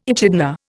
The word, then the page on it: echidna: Indian